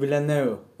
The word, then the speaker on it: Villeneuve: Deniz Villeneuve.